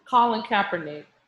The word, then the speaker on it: Colin Kaepernick: Did I say it right? I can't say that name wrong. I said it right. – Colin Kaepernick.